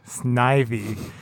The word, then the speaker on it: Snivy: First and foremost, it is pronounced Snivy. I don't know. – Did all of us say Snivy? – Yeah, you all said Snivy. I'm just like, what the hell, Snivy? What? There's no E. It's S-N-I-V-Y.